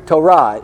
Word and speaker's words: Torah: This is Hebrew Torah. It's pronounced T-O-W-R-A-H. It's not spelled that way, Torah.